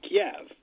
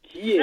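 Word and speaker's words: Kyiv: However, Ukrainians, which is a different language than Russian, prefer the pronunciation, and I can't do it well. – Kiev. No, I'm not great at it. It's the first I. It's not Kiev.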